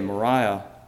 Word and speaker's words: moria: The Greek word used there for foolishness is a word that's spelled M-O-R-I-A, Moriah.